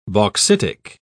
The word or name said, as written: bauxitic